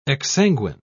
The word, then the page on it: exsanguine: British English